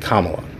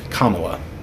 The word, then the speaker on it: Kamala: Let me get it right. Kamala. He can't say it right. Biden can't fucking say it. At least I can fucking correct myself and say it. Kamala.